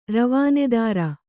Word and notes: ರವಾನೆದಾರ: Kannada

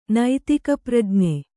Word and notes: ನೈತಿಕಪ್ರಜ್ಞೆ: naitika prajne

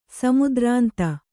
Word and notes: ಸಮುದ್ರಾಂತ: samudrānta

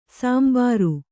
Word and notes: ಸಾಂಬಾರು: sāmbāru; Kannada

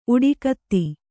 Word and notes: ಉಡಿಕತ್ತಿ: Kannada

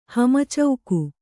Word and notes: ಹಮಚೌಕು: hama cauku

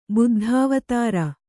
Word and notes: ಬೌದ್ಧಾವತಾರ: bauddhāvatāra

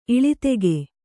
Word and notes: ಇಳಿತೆಗೆ: iḷitege

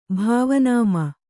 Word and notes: ಭಾವನಾಮ: Kannada

